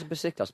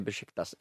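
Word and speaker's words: Beşiktaş: Where's Besiktas became Besiktas in the last year? Because people went, that's how you pronounce it.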